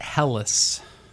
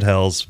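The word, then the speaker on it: Helles: So I've always pronounced it Hellas. – We actually went to Germany to do some research before we opened our German-inspired beer hall, and they... – You went to Hell's and back? – Yeah, we went to Hell's and back. It was a trip. And they said Hell's, but...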